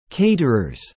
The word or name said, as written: caterers